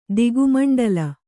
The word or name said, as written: ದಿಗುಮಂಡಲ